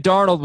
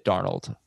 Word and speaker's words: Darnold: No, that's Darnold. I had that one right. Sam Darnold was limited in practice. Looks like he's going to play, hopefully. Optimistic. Jamison Crowder missed practice. So I'd sit Darnold if Prater doesn't play.